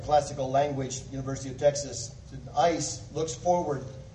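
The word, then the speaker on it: ice (εἰς): It's pronounced ice, like an ice cube or ice bucket.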